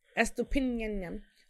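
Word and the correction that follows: Estupiñán: Estupinyanyam. – Estupinyan. – Yeah, finish. – Estupinyan. That's how you pronounce Estupinyan.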